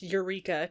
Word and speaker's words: Yreka: I think it's pronounced Eureka, California, but it's not spelled that way. It's spelled Y-R-E-K-A. – I'm pretty sure it's Eureka.